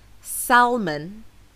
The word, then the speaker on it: salmon: This word is often mispronounced by English language learners because the L in this word is actually silent, and this can be confusing for non-native speakers. However, the correct pronunciation does not include the L sound. So it's not salmon, it's salmon, as in, the salmon in the salad is so fresh.